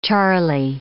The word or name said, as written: Charlie